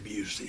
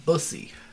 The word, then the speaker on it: Busey: Pronounced Gary Busey. Gary Busey. – Or Bussy.